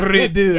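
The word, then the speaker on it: Redux: But it's spelled the French way, so it's R-E-D-U-X. – Oh, but it's like naked gun. Redo. – Yeah. Redo. Yeah.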